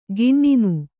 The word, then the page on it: ಗಿಣ್ಣಿನು: giṇṇinu